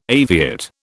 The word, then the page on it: aviate: British English